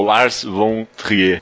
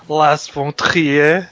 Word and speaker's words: Lars von Trier: Oh, Las Pontrier. Mas não é assim a pronúncia correta? – É, é, é mas é, no Brasil é Lars, Lars Tá,